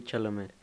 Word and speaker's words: Chalamet: bueno, también sale Timothy Chamlet. Eh, se pronuncia Chamlet, güey. Timothy Chalamet. Bueno, Chalamet, Chalamet, sí.